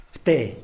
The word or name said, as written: pte